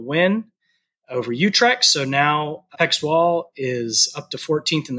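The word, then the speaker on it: PEC Zwolle: I found out recently from a friend that we've been pronouncing it wrong. The correct pronunciation of Bram's team is Peck Zwall.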